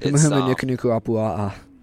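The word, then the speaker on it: Humahuma Nukunuku Apua'a (Humuhumunukunukuāpuaʻa): Humahuma Nukunuku Apua'a.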